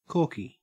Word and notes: corky: Australian English

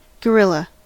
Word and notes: gorilla: American English

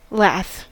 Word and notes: lath: American English

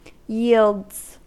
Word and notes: yields: American English

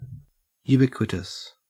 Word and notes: ubiquitous: Australian English

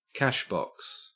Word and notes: cashbox: Australian English

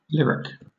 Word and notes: lyric: British English

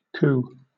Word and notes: coo: British English

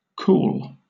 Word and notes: cool: British English

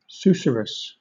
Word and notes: susurrus: British English

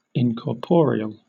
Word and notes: incorporeal: British English

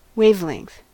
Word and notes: wavelength: American English

